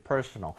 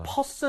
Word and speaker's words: personal: 그래서 개인적이라고 얘기를 할 때 personal, right? 보겠습니다. Just like that. Alright, 형용사고요. 이거 발음할 때 중간에 r 있는 거 그냥 넘어가시면 안 돼요. – Yeah. – personal